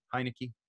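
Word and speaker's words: Heinicke: I mean, we're pretending Taylor Heineke. Am I even saying his name right? Is that his name, Taylor Heineke? – Yeah.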